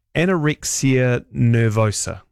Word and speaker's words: anorexia nervosa: This text says, "Hi, our daughter has anorexia nervosa." Mm-hmm. I say that right? – Yes.